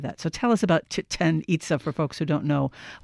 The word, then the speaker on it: Chichen Itza: No, it's Maya. And so in the Maya language, it's Chichen Itza.